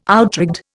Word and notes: outrigged: Indian

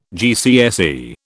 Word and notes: GCSE: British English

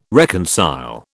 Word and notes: reconcile: British English

